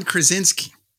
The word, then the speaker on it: Krasinski: The John, I pronounce his name wrong. Krasinski? – John Krasinski.